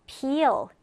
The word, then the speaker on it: peel: 약간 달라요. pill, peel. 이가 두 개죠. 그래서 이 발음을 좀 길게 해줍니다.